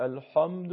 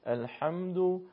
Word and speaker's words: الحمد: بسم الله الرحمن الرحیم، الحمد نمیشه الحمد، این اشتباهه. اگر درشت و پرحجم تلفظ بشه اشتباهه. الحمد الحمد لله رب العالمین.